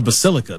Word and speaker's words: Basilica: The Basilicia? – Yeah. – There you go. That's what... That's. – It's not... Yeah, that's not how you say it. – I just have a picture of it. The Basilica.